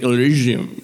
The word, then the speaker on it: Elysium: he's playing the first link that says Elysium pronunciation on Google, and it sounds like Elysium. So it's if you play it, they show the sir, and I don't know what the company's called. So it's it's Elysium.